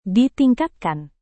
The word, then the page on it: ditingkatkan: Indonesian